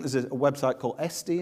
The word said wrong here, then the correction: Etsy: is it Estee? Etsy. I can never pronounce it. It's where you buy these cool things that you can't buy on the high street. What's it called again? Etsy.